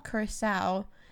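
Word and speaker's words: Curaçao: Curacao, and Sprite.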